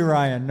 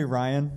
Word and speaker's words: Ryan: It's actually Ryan, no B. – What's that? – It's actually Ryan, no B.